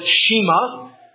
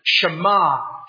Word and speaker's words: Shema: Some people may look at it and pronounce it Shema. It's actually the first Hebrew word of verse 4, and that's how it gets its title. Shema, Shema Yisrael, Adonai Eloheinu, Adonai Echad.